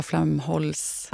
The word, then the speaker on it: Flamholc: Jag sa Naomi Flamholk, men du sa Flamholls.